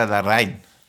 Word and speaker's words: Larraín: Ja, also meine Freundin hat versucht, es mir zu erklären, es ist ja Larraín.